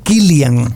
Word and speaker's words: Cillian: Recuerda que en irlandés se pronuncia Killian.